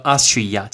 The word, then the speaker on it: Austria: 奥地利叫做 Austria，请不要把它念成 Australia，Australia 是澳洲。